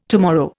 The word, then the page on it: tomorrow: Indian